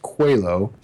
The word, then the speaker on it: Coelho: I did not realize this was the way the name was pronounced, but I was told it's pronounced Coelho, so Coelho Medallion. But fear not, I was mispronouncing it for like a year.